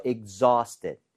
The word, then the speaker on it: exhausted: AU나 AW 스펠링은 대부분 어에 가깝게 발음이 돼요. – 네. – 아우가 아니라. – 예. – So exhausted. – Exhausted. – Exhausted. 이렇게 발음을 하는 게 맞습니다.